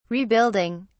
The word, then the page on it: rebuilding: British English